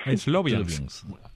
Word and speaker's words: Sloviansk: ciudad de Laviansk. ¿Se pronuncia así? – Sloviansk.